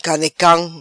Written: Canecam

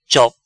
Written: Chop

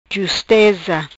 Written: Justeza

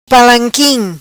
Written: palanquim

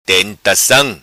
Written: Tentacam

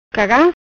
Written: Cagam

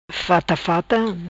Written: fata fata

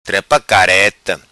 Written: Trepa Careta

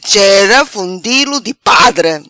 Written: Chera Fundilho Di Padre

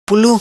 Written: Pulu

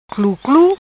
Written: Cluclu

